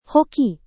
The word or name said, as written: hoki